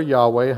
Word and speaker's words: Yahweh: since the Hebrews are not fully persuaded as to how they pronounce that word because of what they have done in the past. We've kind of Latinized it to say Jehovah. Yahweh may be more of the Hebraic way of saying it.